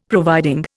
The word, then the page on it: providing: Indian